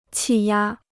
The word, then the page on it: 气压: qì yā